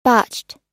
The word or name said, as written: botched